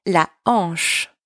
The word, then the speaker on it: la hanche: Careful here, you do not pronounce the H. The H is silent in French. La hanche.